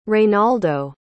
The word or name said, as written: reinaldo